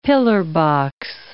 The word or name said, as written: pillar-box